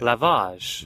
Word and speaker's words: lavage: Lavage.